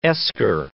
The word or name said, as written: esker